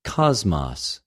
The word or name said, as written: κοσμος